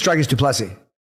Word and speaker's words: Dricus du Plessis: I think it's Dricus du Plessis.